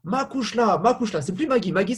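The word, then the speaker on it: mo chuisle: Et dans ses combats, avant des grands combats notamment, il va lui offrir un peignoir de boxeur où il y aura écrit quelque chose en irlandais dans le dos, que tout le monde prononce Makushla.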